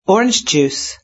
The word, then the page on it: orange juice: British English